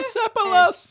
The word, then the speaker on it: Persepolis: I'm probably butchering that pronunciation, but it's Persepolis. – Yeah. Persepolis. That seems right.